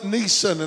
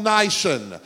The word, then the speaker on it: Nisan: On the 10th day of the Jewish month of Nisan, I used to call it Nisan, and everybody says that you pronounce it wrong when you pronounce it. It's called Nisan.